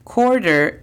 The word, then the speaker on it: quarter: La pronunciación fonética de esta es D-A-I-M. Dime. Por último, tenemos quarter. Quarter es equivalente a 25 centavos. La pronunciación fonética es C-O-R-D-R. Quarter.